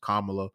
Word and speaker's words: Kamala: I say Kamala. It's pronounced Kamala Harris. She's the first female vice president, first African American, first, you know, person of African American descent.